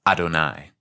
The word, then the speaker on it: Adonai: Finally, here in Exodus, through Moshe, we have been given the Tetragrammaton, the famous four-letter name of God, traditionally pronounced Adonai.